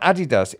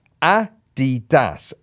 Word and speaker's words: Adidas: The bit about Adidas, it's pronounced Adidas, as the guy's name was Adidasler.